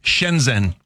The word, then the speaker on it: Shenzhen: How do you say it? – Shenzhen.